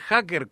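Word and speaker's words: jáquer: Con saber hacker con J y acento en la A, como suena, estamos bien, no quedamos mal. Me hackearon. – Y sin ¿no? – K, con Q. – Exacto, con Q. – Q. – Así como escribir el hacker, como te está sonando con J, con acento en la A y con la Q. – Bueno. – Y U, E. Vamos a deletrearlo. J, A con acento, Q. U-E-R.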